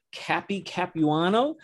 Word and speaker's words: Capuano: This is Jim, along with Dave Capuano.